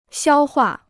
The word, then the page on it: 消化: xiāo huà